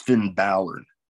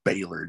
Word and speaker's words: Balor: Yes, that's Finn Balor. People that say Baylor, just what the fuck? Pronounce the damn name right.